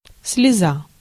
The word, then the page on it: larme: [la.ʁm]; France